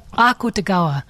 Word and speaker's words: Akutagawa: Yeah, the stress is on the ah. So Akutagawa.